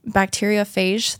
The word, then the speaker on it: bacteriophage: I want to believe that these solutions will work too, but, other than bacteriophagy therapy, am I saying that right? – Bacteriophage therapy, which a lot of people mention.